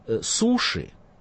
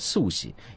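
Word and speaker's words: суси (すし): Поэтому, когда у нас говорят суши, то это неправильно, потому что японцы говорят суси.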